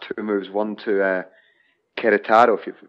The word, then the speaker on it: Querétaro: Querétaro. Oh, there you go. Fantastic. Perfect. Thank you. – Perfect. Querétaro, and uh, then Fluminense, and both times were were fairly critical of.